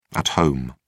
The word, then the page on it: at home: British English